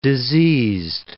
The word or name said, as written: diseased